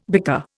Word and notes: bicker: Indian

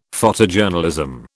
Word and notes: Photojournalism: British English

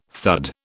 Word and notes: thud: British English

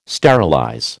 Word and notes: sterilize: American English

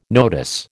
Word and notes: notice: American English

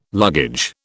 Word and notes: luggage: British English

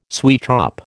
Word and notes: sweetshop: American English

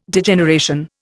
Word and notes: Degeneration: Indian

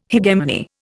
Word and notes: hegemony: Indian

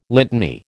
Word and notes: litany: American English

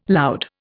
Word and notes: loud: Indian

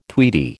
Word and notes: tweedy: American English

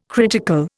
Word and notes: critical: Indian

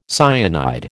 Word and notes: cyanide: American English

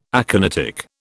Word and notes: aconitic: British English

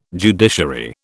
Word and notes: judiciary: British English

